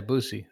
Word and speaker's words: Boosie: Are we saying it right? – What? – Boosie? – Yeah, Boosie. That's right.